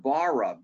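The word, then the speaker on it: bara (ברא): It's the Hebrew word bara, B-A-R-A, if we spell it phonetically.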